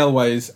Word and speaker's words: Elwes: Yes, Elwes. – Elwes, isn't it? Carrie Elwes. – I just saw him in The Princess Bride last night. – He the first film with Carrie Elwes and Lee Whannell, and Carrie Elwes survives, and turns out to have been an apprentice of Jigsaw's since then.